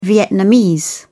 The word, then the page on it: vietnamese: British English